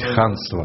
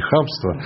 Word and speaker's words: ханство: Здесь надо очень четко Крымское произносить ханство. Ханство, именно, а не хамство.